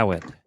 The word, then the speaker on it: Awad: And the last name is like Awad. Awad, yeah. – Awad.